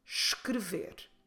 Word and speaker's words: escrever: E-S-C-R-E-V-E-R. Escrever. Don't forget to start with a soft sh, ignoring the first vowel at all. Escrever.